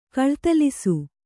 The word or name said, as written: ಕಳ್ತಲಿಸು